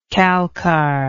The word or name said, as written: calcar